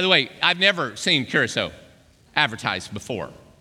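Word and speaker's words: Curaçao: Now, I would never have pronounced Curacao the right way, but it was Curacao, and I'm like, I don't know where that is, and it's C-U-R-A-C-O, Curacao, and I'm like, oh, Curacao, all right, that's the right way to say it.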